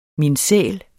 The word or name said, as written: minsæl